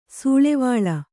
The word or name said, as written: ಸೂಳೆವಳ್ಳ